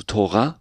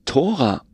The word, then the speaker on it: Tora: Und genau diese Frage, das finde ich das Spannende, weil es ist sowohl als auch richtig, ja, also Tora wäre hebräisch, während Tora eine deutsche Aussprache ist, eine rein deutsche Aussprache, aber eigentlich gibt es dieses Wort Tora nicht. Es ist einfach nur nach deutschen Regeln, Ausspracheregeln, weil es mit Thora geschrieben ist, ja, aber wenn man es auf Hebräisch schreibt, ist es natürlich nicht so.